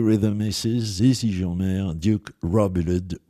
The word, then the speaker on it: Robillard: Et ensuite, alors là, accrochez-vous au bastingage ou à ce que vous pourrez, le guitariste et chanteur Duke Robillard, que je pourrais prononcer également, comme je l'ai entendu, outre-Atlantique, Duke Robillard, ben oui, pourquoi pas, mais Duke Robillard, je trouve que ça sonne bien, avec Glamour Girl.